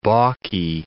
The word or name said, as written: balky